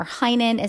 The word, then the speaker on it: Heynen: You can find me on Instagram at Heinen Counseling and Coaching. Last name, or Heinen, is spelled H-E-Y-N-E-N, Heinen Counseling and Coaching.